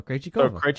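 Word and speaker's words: Krejčíková: Krech Krejcikova is that? – That I mean, sounds right. Krejci me, right? and then Kova. Krejcikova.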